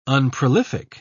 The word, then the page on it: unprolific: British English